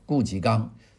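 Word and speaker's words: 顾颉刚: 那我们今天讲讲这个顾霁刚先生。顾霁刚的顾霁刚的啮字呢，有些人读成吉，他其实是节，是一个吉吉利的吉字。它右边呢是一一叶子两叶子的叶啊，呃，我们读节。这个顾霁刚是江苏的苏州人啊，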